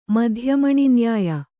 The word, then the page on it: ಮಧ್ಯಮಣಿನ್ಯಾಯ: madhya maṇi nyāya